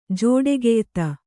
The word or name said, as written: ಜೋಡೆಗೆಯ್ತ